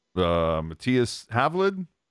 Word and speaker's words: Matthias Havlid: Add in names like Brandon Coe, Daniil Gushkin, Matthias Havlid. Am I saying that correctly? Feels like it. – I believe so, yeah.